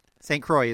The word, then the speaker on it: Croix: St. Croix is correct. A little bit of tricky there with the pronunciation of the IX, but you're correct.